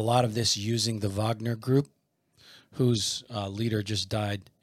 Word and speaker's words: Wagner: How the Russian mercenary group, it's spelled Wagner, but pronounced Wagner, it's Putin's private army, was behind the coup in Niger.